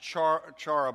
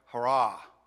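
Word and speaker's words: hurrah (χαρά): It looks like it should be pronounced chara, but it's more like hurrah, hurrah. The C's kind of silent there.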